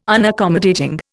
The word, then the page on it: Unaccommodating: Indian